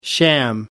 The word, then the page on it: sham: American English